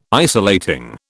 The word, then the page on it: isolating: British English